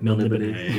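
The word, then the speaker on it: Melniboné: Melnibody.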